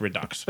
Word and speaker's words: Redux: I think it should be pronounced Redo. I think the X should be silent, but apparently it's Redux.